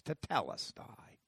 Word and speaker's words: tetelestai: Tetelestai is the right pronunciation. But we'll go tetelestai because that's what we all know.